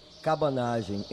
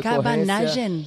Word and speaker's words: Cabanagem: Cabanagem, em decorrência. – Cabanagem, espera, decilo mais despacio. – Cabanagem.